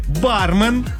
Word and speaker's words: бармен: Далее произносите «бармен».